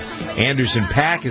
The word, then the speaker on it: Anderson .Paak: teaming up with Anderson .Paak.